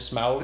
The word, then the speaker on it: Smaug: The desolate, you know, Desolation of Smaug? Well, that's how you say it.